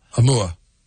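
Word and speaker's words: Imva: Amua. But it's spelled with a V. I-M-V-A. But it's Amua. That's how you say it.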